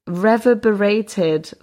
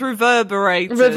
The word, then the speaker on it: reverberated: oh, reverberated fart. – I think that's reverberated. – I knew I was saying that wrong.